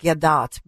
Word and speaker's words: Gadot: And in this, the weird piece of this is Gail, I know it's pronounced Gadot, but Gail Gadot, who plays the evil stepmother, is actually so beautiful, and she's been silent.